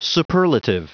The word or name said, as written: superlative